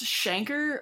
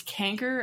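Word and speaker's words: chancre: Google says this is pronounced shanker, but I think I've only ever heard it pronounced canker, as in like canker sore.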